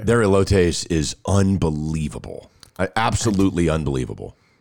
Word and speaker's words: elotes: And the other thing that's great that they make, elotes. Is that how you say it? – Yeah, elotes is delicious.